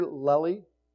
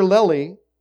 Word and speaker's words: Lely: Have you ever heard of a painter named Sir Peter Lely? I never had heard of him before, didn't know anything about him. Sir Lely, and I hope I'm saying it right, L-E-L-Y, Lely or Lely, Lely I'll call him, was born about 400 years ago.